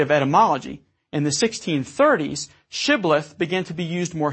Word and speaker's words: Shibboleth: If he said no, they then said, "Very well, say Shibboleth." If anyone said Sibboleth, because he could not pronounce it, then they would seize him and kill him by the fords of the Jordan. 42,000 Ephraimites were killed on this occasion. So because the Ephraimites could not pronounce the phoneme sh in Shibboleth, this was the perfect test word or password.